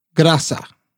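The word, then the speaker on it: Graça: And then I think you say Graca, G-R-A-C with a little curly Q thing on the C. – Is that? Yeah, I don't know. I don't know how to pronounce it. – It's Graca. They got married when Nelson was 80 in 1998, and they were together until his death for 15 years.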